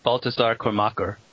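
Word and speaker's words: Baltasar Kormákur: Oh well, I say it Baltasar Kormacher.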